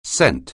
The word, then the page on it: cent: anglais